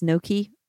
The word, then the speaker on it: Nuki: That's N-U-K-I. I believe it's supposed to be pronounced Noki, actually, because it's no key.